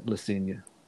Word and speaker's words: Le Cygne: group, I think, Le Cygne, uh, the Swan. Did I say that right? Le Cygne.